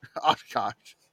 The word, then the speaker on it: Oshkosh: Wisconsin Oshkosh. Oshkosh. – I would like... – Yes, as in Bagosh.